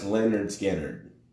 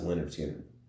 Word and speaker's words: Lynyrd Skynyrd: Anyway, it's pronounced Lynyrd Skynyrd. That's the album name. Pronounced Lynyrd Skynyrd. – Lynyrd Skynyrd. – Yep, that's what it's called. It says pronounced Lynyrd, like broken down into how you would pronounce it in the English language, to Lynyrd Skynyrd.